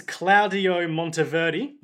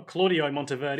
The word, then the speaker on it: Claudio Monteverdi: His favourite musician is Claudio Monteverdi, or Claudio Monteverdi. – Thank you for pronouncing that correctly.